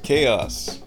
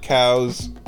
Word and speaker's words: chaos: The word chaos is pronounced chaos, not cows, not chouse.